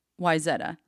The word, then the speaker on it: Wayzata: the town is actually called Wayzata, but it's spelled so people pronounce it Wayzata.